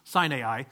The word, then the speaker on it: Sinai: He said, the one from the Mount Sinai. Now, it's technically pronounced Sinai, but we've always mispronounced it in Christianity, so sometimes I do it.